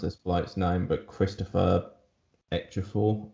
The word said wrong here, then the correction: Chiwetel Ejiofor: Chiwetel Ejiofor?